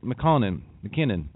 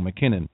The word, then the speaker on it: McKinnon: It says Sony is now negotiating with Kristen Wiig, as well as Saturday Night Live players Leslie Jones and Kate McKinnon. I always say that wrong. McKinnon.